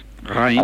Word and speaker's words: Reims: según mi amigo francés Alex, se pronuncia Reims. Reims.